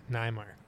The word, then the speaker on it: Neymark: My my ancestors pronounced it Neymark. – Neymark.